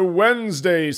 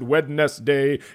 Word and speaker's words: Wednesday: It's a Wednesday. Some people pronounce it Wednesday if they pronounce all of the letters in a word.